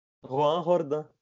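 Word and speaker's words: Joan Jordán: Como se fala? – Juan Jordán.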